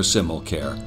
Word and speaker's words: Simmelkjaer: Sure, there have been hills and valleys along the way for Simmelkjaer, and by the way, that's how you pronounce it. It's Danish, and when you see it in print, you're going to need a pronunciation guide.